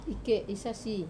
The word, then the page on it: ke sasi: wamesa